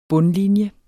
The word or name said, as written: bundlinje